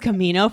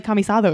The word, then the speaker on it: Camisado: I thought it was Camino for the longest time until I like literally put it down for this because then I was like, oh shit, no, it's like Spanish. It's like Camisado or something.